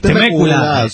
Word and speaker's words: Temécula: Temécula, yo sé, sí, más cariñoso, así que nos quedaremos con Temécula, Dice, bueno, con el acento en la segunda, Bueno, es todo.